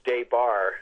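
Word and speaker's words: Des Barres: You know, it's pronounced Debar.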